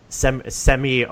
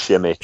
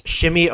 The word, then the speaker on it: Semi: I got really excited about the pronunciation of Semi Ogilvy's name or whatever. – I still trust it. I think it's Shimmy. – Shimmy Ogilvy was how they told you to pronounce it.